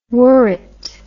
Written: worrit

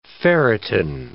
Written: ferritin

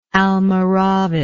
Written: almoravid

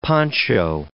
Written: poncho

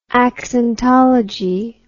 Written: accentology

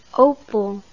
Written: opel

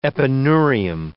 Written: epineurium

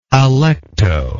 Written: alecto

